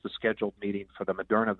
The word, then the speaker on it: Moderna: One is Pfizer, and the other is what, Moderna? Am I saying that right? – Yes. Yeah, Moderna.